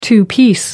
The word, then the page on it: two-piece: American English